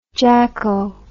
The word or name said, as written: jacko